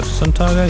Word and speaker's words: Suntago: And we also played you a real uplifting thing from Nuestro called Suntago. Suntago, that's how you say it.